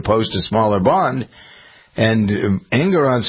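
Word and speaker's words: Engoron: Engeron, is that how you pronounce his name? – Uh-huh, that's right. – I think it's Engeron, yeah.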